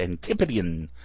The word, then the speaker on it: Antipodean: That's how they pronounce this. Got to throw that tip in there. It's Antipodean.